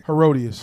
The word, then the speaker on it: Herodias: So what happened was when John was out doing his thing, he busted out Herodias, correct? Did I say it right? – Herodias. – Herodias.